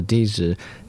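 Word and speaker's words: di (笛): The Chinese pronunciation for flute is di. It shares the same pronunciation as that of another word that means purification.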